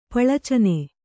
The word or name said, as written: ಪಳಚ್ಚನೆ